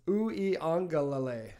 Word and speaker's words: Uiagalelei: It's Uiagalelei.